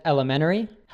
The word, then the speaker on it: elementary: So that's that NT, which in the US we say, we kind of drop the T. In the UK, you tend to pronounce it, right? – Yeah. So the full form would be elementary, but we don't really pronounce that schwa sound, the T-ri. We would connect it and say elementary.